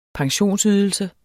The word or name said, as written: pensionsydelse